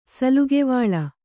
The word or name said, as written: ಸಲುಗೆವಾಳ